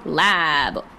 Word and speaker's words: Laab: But it's also, I think it's spelled the same way. Laab. – Yes, it's lab.